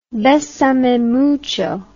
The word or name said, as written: besame mucho